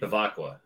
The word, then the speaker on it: Bevacqua: I didn't see who the name was. Bevecchia? – Pete Bevacqua. Oh, okay. Bevacqua.